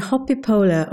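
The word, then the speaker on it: Hoppípolla: I looked it up and actually Hoppipola or Hoppipotla, as it's pronounced in Icelandic, maybe not, means hopping in puddles.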